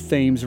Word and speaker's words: Thames: At the time, New London occupied both sides of the Thames River. And yes, that's the way it's pronounced in Connecticut.